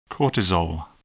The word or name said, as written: cortisol